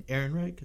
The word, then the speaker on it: Ehrenreich: And then as for Alden Ehrenreich, is that how you pronounce it? – Yeah.